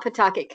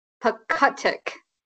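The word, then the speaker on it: Pawcatuck: and it's not Pawtucket, Connecticut, it's Pawcutuck, Connecticut. – Pawcutuck? What was that? – It's spelt like paw cat tuck.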